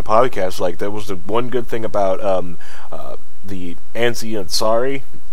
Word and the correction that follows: Aziz Ansari: I'm butchered that. Oh, man, why am I so bad with names? – Aziz Ansari, and they're screwing him over so hard.